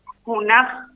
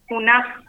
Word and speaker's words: Junaj: En Wichí se dice Junaj, buenas tardes. – Perdón, ¿cómo se dice? ¿Lo reiteramos? – Junaj. – Perfecto.